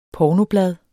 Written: pornoblad

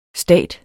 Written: stat

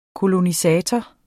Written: kolonisator

